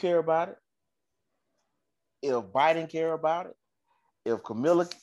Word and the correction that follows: Kamala: what's how you say it? Camilla? – Kamala. – Kamala.